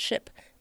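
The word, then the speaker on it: SHPE: We pronounced it SHIP.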